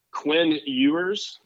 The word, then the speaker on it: Quinn Ewers: Mine is Quinn Ewers. Is that how you yes. – say his name? – Yes. Yes, I'm happy yes.